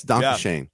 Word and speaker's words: Dankeschön: Oh, Dankeschön. – Yes, Dankeschön.